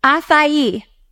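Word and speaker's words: açaí: Acai. – Okay, not acai. – No. – Okay, so I've been saying acai, and my friends have been telling me acai.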